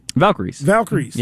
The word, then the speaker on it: Valkyries: How do you say it? – Valkyries. Yeah, Golden State Valkyries.